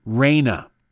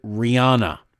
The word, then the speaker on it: Rania: Raina. It's very short, two-syllable, Raina. R-A-N-I-A.